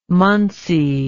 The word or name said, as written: munsee